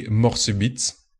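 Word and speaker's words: Mort Subite: In French, it would be Morsubeet, une Creek Lambic.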